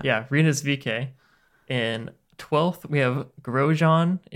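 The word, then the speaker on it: VeeKay: It's VK. That's VK.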